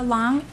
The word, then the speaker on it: Long: Tara Long, L-O-N-G.